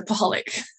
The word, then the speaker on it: Paholek: It rhymes with alcoholic, so Paholic.